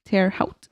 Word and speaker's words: Terre Haute: It's spelled H-A-U-T-E. Oh. Haut? Terre Haute? – I don't know. Hot, I think.